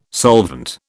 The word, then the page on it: solvent: British English